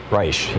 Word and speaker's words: Reich: Reich, yeah, there you go. The English pronunciation.